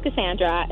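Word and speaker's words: Kassandra: My name is Cassandra.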